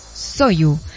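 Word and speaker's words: Soyu (소주): ¿se pronuncia Soyu o Soju? Soyu.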